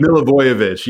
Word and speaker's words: Milivojevic: Milivojevic, yeah.